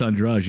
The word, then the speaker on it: Andrade: Is it Andrade or Andrade? Oh, because it's spelled. Well, she's Portuguese. She's Brazilian. – Brazilian. – She's Portuguese. – Yeah. – you know the Portuguese the language. – They make up their own pronunciations. On the letters. They have their own like a. So it's a I want to say Andrade because you know because it's Spanish. But it's Andrade, you're right.